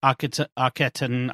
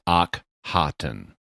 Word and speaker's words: Akhaten: So the problem is in English, we don't have the H sound in standard English. And so we represent it with KH. But the doctor is splitting it into two separate consonants, Akhaten.